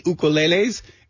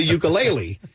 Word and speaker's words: ukulele: You know, if I were talking to someone from, the mainland, I'd be like, hey, do you play the ukulele? Because that's how it's known. But in Hawaii, it's actually pronounced ukulele.